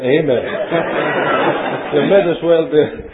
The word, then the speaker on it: amen: And that means, according to this particular Bible, which I believe is correct, the first letter is pronounced as in A-H, ah, as in father, F-A-T-H-E-R. So that the correct pronunciation of the word is unquestionably amen.